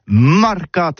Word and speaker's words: marcat: Eh bien, dis ça à tous, mais le mercat de l'Uni ou le mercat des rebelles, mais en gascogne toulousain, c'est prononcé sur les marcat, avec un A, marquette.